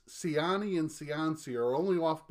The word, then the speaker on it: Ciani: If you recall from our last episode, I had sort of an accusatory tone when the name Suzanne Ciani came up. I accused you of butchering her name to Ciancy.